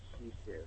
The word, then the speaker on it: Shih Tzu: Is that like a Shih Tzu? – So, yeah, the correct pronunciation is Shisu.